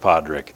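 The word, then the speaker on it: Pádraig: Not sure if that means much to you, but for my part, I love the story of Padraic. I'm calling him Padraic because that's how you'd say his name in Irish.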